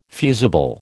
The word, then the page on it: fusible: American English